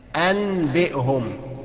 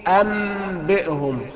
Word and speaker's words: أنبئهم: اولا هقلب النون ميم هتبقى ام، واخفيها في الباء. اوعى تقول لي انبئهم، خطا.